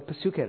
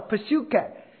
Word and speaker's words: Psyche: 但是马太、马可、路加这个 Zoe 跟另外一个字可以互用，另外一个字是什么呢？Psyche，Psyche 就是 psychology 的那个字根，P S Y C H E，Psyche，希腊文念 P 要发音的，英文 P 就省掉了，所以指定叫 psychology，前面有个 P 的。 psychology 从 psyche 来的，那个也是生命，动物生命、人类生命都可以叫 psyche。